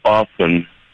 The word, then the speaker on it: often: Why did we start pronouncing the word often with the hard T?